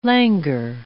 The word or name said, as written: languor